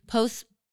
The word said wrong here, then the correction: posthumous: Posthumous. I was saying it wrong.